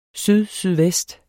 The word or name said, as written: sydsydvest